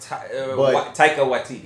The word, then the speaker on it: Taika Waititi: We know it was Tahiti. Okay, I never could say his name right there. Directed it. – Taika Waititi.